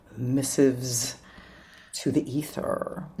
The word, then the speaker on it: Missives to the Ether: Yeah, thanks for having me on Missives to the Ether.